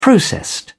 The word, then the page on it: processed: British English